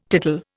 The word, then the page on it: tittle: Indian